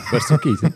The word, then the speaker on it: Buster Keaton: Voor de liefhebbers, er is een hele mooie video voor Gemini 1.5 met een video van Buster Keaton. Zeg ik dat goed? – Als jij refereert aan Buster Keaton, dan zei je dat goed. Ja, ja, Buster Keaton.